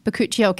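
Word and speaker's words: bakuchiol: But a fantastic ingredient, bacuchiol. How do you pronounce it? – Bacuchiol. – Yeah, you did it.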